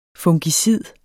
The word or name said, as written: fungicid